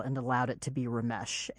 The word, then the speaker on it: Ramesh: Well, my parents named me Ramesh. Uh-huh. That is the classical pronunciation of those letters.